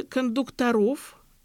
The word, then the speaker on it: кондукторов: Там готовили кондукторов, именно с таким ударением, а кондуктора – это инженеры, унтер-офицеры.